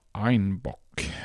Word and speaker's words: Einbeck: Och på den bayerska dialekten uttalades tydligen Einbeck som Einbock,